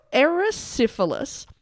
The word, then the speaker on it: erysipelas: But that the red marks were in fact caused by, I'm going to say this wrong, erysipelas? – Erysipelas? – E-R-Y-S-I-P-E-L-A-S. – Okay.